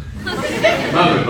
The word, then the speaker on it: Maghrib: Rhymes with Bagrib. Maghrib. Okay, very good everybody, right? Maghrib prayer, when the sun goes down, what is due to Allah at that time?